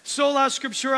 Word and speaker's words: Sola Scriptura: So, and I'm going to say this wrong. Sola Scriptura. Eh, Chris, did I say that right? Did I nail that? Sola Scriptura, I think that's right.